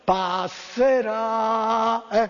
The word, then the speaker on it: Passerà: E veniamo al 94, Alejandro Baldi vince anche fra i Big con Passera. No, c'è l'accento. Passerà, quella lì.